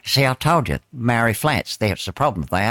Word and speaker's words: Māori: That's how you say Māori. Māori. Māori. That's the correct pronunciation.